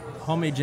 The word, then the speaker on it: Homage: It's called, how would you pronounce that in Australia? – Homage in Australia. Homage. Or homage here.